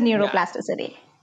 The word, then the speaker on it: neuroplasticity: I might be butchering this word, so correct me if I'm wrong, but is it neuroplasticity or? – Yes, it is neuroplasticity.